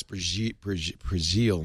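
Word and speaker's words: Breazeal: Maybe, by the way, it's Brazil, not Brashears. I keep saying her name wrong.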